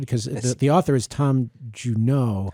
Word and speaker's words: Junod: Yes, so he changed, the son changed the pronunciation of his name in college. He was taking a French class, and the teacher started pronouncing his name Juneau, and he said, I like it, I'm going to change it.